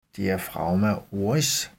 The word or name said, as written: diafragma oris